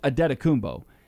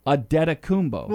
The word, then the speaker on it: Antetokounmpo: He butchers Antetokounmpo. They try to overpronounce it. They try to pronounce the N and the T, and it's really Antetokounmpo.